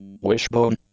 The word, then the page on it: wishbone: Canadian English